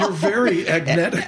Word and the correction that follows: agentic: Agentic. Yeah. All right. – And also, remember, how you say it? – Agentic? – Agentic AI. – Agentic. That's what our folks here say.